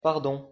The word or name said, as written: pardon